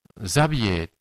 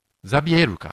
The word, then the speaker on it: ザビエル: そしてこれはその日本と比べができるんじゃないかなと思うんですけれども、 560 年前、ザビエっていう宣教師が日本に来て、ザビエ発音間違えてるなら、ザビエルか。英語でグゼビアって言うんですけど、